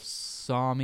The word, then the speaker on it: Somme: Snoopy, was shot down and killed during the Battle of Somme. It's actually both pronunciations are correct.